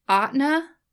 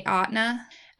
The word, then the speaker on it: Ahtna: And then Aetna, I think is how it's pronounced, A-H-T-N-A, Aetna. Aetna Inc.